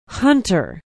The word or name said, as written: hunter